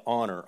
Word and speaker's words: honor: So, children is obey and honor, honor and obey. And, and I tried to go for alliteration, and that stupid silent H came in, but so we went with O.